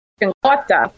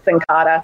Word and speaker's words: Cincotta: It's actually pronounced Cincotta, if you were to say it in Italian, but we stick with Cincotta.